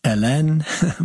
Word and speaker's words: Hélène: yeah, my daughter is actually named Eleni, but in French, Hélène.